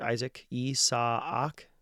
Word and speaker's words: Isaac: And that word, Isaac, Isaac, two syllables, rearticulated.